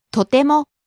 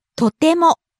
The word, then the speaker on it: とても: Rises on the second syllable, te, and stays high. – とても。